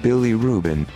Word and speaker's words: bilirubin: Bilirubin. – Bilirubin? It's B-I-L-I-R-U-B-I-N, but it sounds like a sandwich.